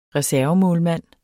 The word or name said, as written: reservemålmand